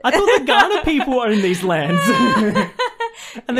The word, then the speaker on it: Kaurna: It's spelt K-A-U-R-N-A. And we pronounce it Ghana.